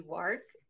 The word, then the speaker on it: WARC: Juan also manages TMW's online community, a regular podcast called Making Sense of MarTech, and frequently collaborates with other publications such as, I want to say WARC, but maybe WARC, is another way of saying it. – You pronounce that like spot on. Most people can't do it. It's like work, but you got it spot on there.